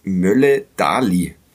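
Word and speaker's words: Møller Dæhli: Der Club hat in ähm, Karlsruhe gewonnen bei der äh, zuvor Mannschaft äh, der Stunde durch ein spätes Tor von, Achtung, Mats Mölle-Dali habe ich in einem St. Pauli-Podcast Millanton gelernt, dass er so ausgesprochen wird. Ich hoffe, ich erinnere mich richtig. Also das R ist stumm und dann ein Dali das ist auch eine Begleiterscheinung äh, diese Saison, dass wir uns am, am Norwegischen äh, versuchen.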